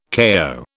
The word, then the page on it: kayo: British English